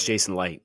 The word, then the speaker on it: Licht: Like, is it Licht or Light? I don't know how to say it. – It's Jason Light.